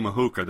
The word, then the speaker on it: Ahuka: Now, as for the name Ahuka, that's just it's a nickname. It's spelled A-H-U-K-A.